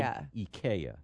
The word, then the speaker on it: IKEA: It's called IKEA. – IKEA. – IKEA.